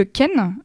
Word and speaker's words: khen: Il y a aussi le ken, qui est une flûte. Et si jamais vous voulez aller regarder, ça s'écrit K-H-E-N.